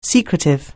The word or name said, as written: secretive